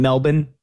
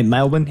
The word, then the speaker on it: Melbourne: Did you have a problem with Noah Eagle saying Melbourne the entire time? Because apparently that's how the locals say it in Australia. They don't, you know, hey, Melbourne, hey, Melbourne.